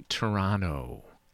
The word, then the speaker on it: Toronto: It's actually Toronto. Like, it's not, you don't put the emphasis on the T. It's Toronto.